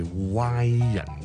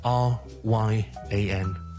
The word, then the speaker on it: Ryan: 蕭叔非常極之中意，咁佢個名咧亦都係叫 Ryan 嘅。有時有啲人咧就可能會將佢讀得歪少少，就變咗好似 Y 人咁啊，Y 人。咁就好似將個 O 音咧，R Y A N 個 O 音咧，就將佢讀成好似 W 咁。